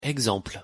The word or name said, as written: exemple